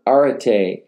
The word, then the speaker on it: arete: Here again, it's the same Greek word, arete, A-R-E-T, long E,